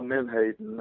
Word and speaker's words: menhaden: like horseshoe crabs or I don't know how to pronounce it, Menhaden? – Yeah, Menhaden, they're sort of the base of the food chain for so many species.